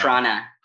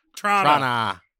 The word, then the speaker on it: Toronto: You just pronounced the T in Toronto. – Yeah, Toronto, not Toronto. – Toronto. – Or Trana. – Trana.